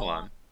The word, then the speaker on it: Shyamalan: Shyamalan, yeah. M Night Shyamalan. – Shyamalan.